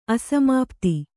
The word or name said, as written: ಅಸಮಾಪ್ತಿ